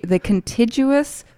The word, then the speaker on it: contiguous: The contiguous 48 states. – Contiguous. – Mm-hmm. Oh, mm-hmm. Yeah. – It's whatever. A hard G.